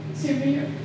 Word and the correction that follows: Smyrna: how do you say that? Yes. Smyrna. Well, then go ahead, because that note was on my heart for you to read that.